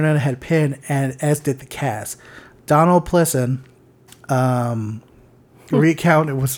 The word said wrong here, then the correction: Pleasence: P-L-E-A, I'm probably saying it wrong, but P-L-E-A-S-E-N-C-E. – Pleasance. – Pleasance. Snake Pleasance.